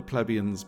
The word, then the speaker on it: plebeian: They are esteemed by pub mates as the expert pint drinkers, set apart from the plebeians by their discerning... plebeians? How do you say that word? – Plebeian, yes. – Yeah, plebeian.